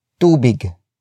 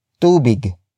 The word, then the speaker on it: tubig: That would be tubig. Tubig. Tubig. Tubig is spelled as T-U-B-I-G.